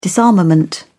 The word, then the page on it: disarmament: British English